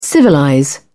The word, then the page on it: civilize: British English